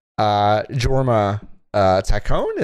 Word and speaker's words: Jorma Taccone: It is like the first thing you see in the credits is Jorma Taccone. Is that how you say his name? – Yeah.